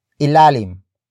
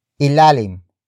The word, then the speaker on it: ilalim: But first, we have ilalim. Ilalim. Ilalim. Ilalim is spelled as I-L-A-L-I-M.